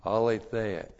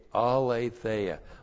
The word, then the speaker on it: aletheia: Aletheia, A-L, long E-T-H-E-I-A. Aletheia.